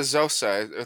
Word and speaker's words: Xhosa: It's the Xhosa because that's spelled with like an X, but I think it's pronounced Xhosa. – I think the XH is actually like a CK type noise.